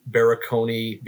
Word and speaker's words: Barakony: Barakony, being four syllables is a very unique last name.